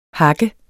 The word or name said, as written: hak